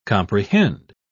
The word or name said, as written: comprehend